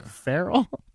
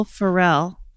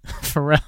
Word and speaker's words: Ferrell: Wait, how do you say it? – Farrell? With an L at the end instead of Farrell? – It's Will Ferrell. – Ferrell.